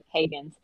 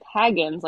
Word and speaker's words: Hagins: You know, when we started this interview, you said my last name correctly, Emily Hagins. And they said, a lot of people say it like Hagins, like Frodo Baggins.